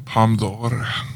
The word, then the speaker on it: Palme d'Or: Palme d'Or.